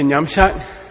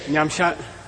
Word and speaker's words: Nyamshat: Make sure the second syllable's right. Okay. Say Nyamshat. Yeshe. Nyamshat. Yeshe.